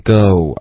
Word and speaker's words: go: 挺好的，可是 go 后面的这个一定要用一个双元音 go，而不是 I'm gonna go。 go 是一个汉语的发音，英文都会有 oh，有个滑动。